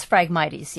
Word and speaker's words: Phragmites: That's Phragmites, yes. – Could you spell that for me? – Phragmites.